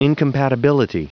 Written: incompatibility

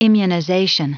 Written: immunization

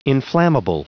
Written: inflammable

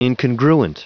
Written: incongruent

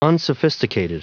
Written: unsophisticated

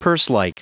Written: purselike

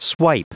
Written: swipe

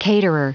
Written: caterer

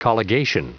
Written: colligation

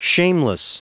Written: shameless

